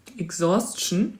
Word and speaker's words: Exhaustion: Exhaustion. Spricht man das so aus? – Ja. Exhaustion. – E-X-H-A-U-S-T-I-O-N.